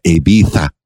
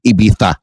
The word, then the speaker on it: Ibiza: How they pronounce Ibiza. You got to put the TH in there.